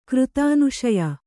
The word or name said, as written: ಕೃತಾನುಶಯ